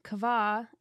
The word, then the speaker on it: qavah: So anyways, I just thought that this word kavah is so beautiful. You guys should look it up. It's spelled Q-A-V-A-H, and that's the Hebrew word for hope.